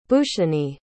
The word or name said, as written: bhooshani